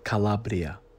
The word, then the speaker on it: Calabria: It is pronounced Calabria. You need to roll the R. We need to hear it next week with the R being rolled.